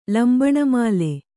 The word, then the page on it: ಲಂಬಣಮಾಲೆ: Kannada